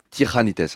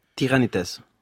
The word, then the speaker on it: Tiganites: Ça s'écrit Tiganites et ça se prononce Tiranites, hein, c'est ça ? – Tiranites.